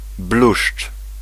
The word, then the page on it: lierre: /ljɛʁ/; France